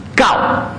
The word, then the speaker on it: 九: Gao.